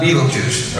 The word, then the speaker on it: Betelgeuse: By the way, it's spelled Beltegeuse, but pronounced Betelgeuse, all right?